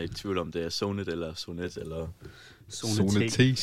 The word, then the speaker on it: ZONET: Og øh, det er nemlig gruppen, eller bandet, Sonet. Er det rigtigt udtalt? Yes. – Sonet.